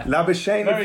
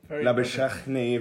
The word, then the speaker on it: Labuschagne: Labuschagne. – It? – Labuschagne. – Good player. – Labuschagne if you're Australian. Labuschagne if you're South African, I hear.